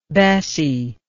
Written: bessie

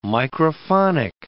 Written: microphonic

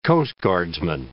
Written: coastguardsman

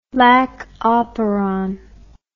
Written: lac operon

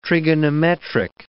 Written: trigonometric

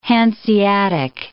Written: Hanseatic

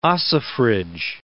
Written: ossifrage

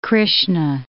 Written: Krishna

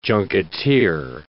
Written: junketeer

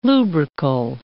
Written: lubrical

